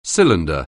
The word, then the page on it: cylinder: anglais